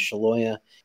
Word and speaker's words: Scialoia: It's Ryan, which is obviously R-Y-A-N. And then the last name is Shaloya, S-C-I-A-L-O-I-A.